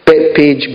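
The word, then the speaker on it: Bethphage: And he comes to the next village up, called Bethpage or Betpage. Betpage, Bet at the start, B-E-T-H, is pronounced Bet, the H is silent, and it talks about a house of.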